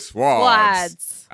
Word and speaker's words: Swords: Do not pronounce the W in Swords. – Look, yeah. – I'm from H-Town. That's how we do it. Swords.